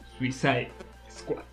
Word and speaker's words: Suicide Squad: Suicide Squad. ¿Lo dije bien? – Sí.